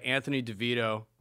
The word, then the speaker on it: Anthony DeVito: I got some guests on, and I'll explain their deal I got Anthony DeVito.